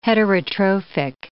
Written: heterotrophic

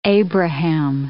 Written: Abraham

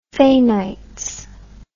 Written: fainites

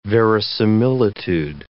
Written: verisimilitude

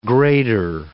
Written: grader

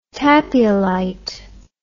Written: tapiolite

